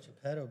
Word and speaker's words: Geppetto: How you pronounce it, Geppetto, right? – Geppetto, baby.